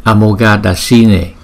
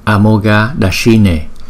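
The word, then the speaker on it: Amoghadarśine: Chúng ta phát âm là Amoghadashine, Amoghadashine, Amoghadashine. Chữ S có dấu sắc chúng ta đọc cong lưỡi lại S, S. Amoghadashine, Amoghadashine, Amoghadashine, Amoghadashine. Namo Bhagavate Amoghadashine Tathagataya Arhate Samyak Sambuddhaya. Namo Bhagavate Amoghadashine Tathagataya Arhate Samyak Sambuddhaya.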